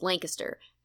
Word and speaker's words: Lancaster: And then the other thing we did not talk about is the pronunciation of Lancaster, which is pronounced Lancaster. And Lancaster is in England.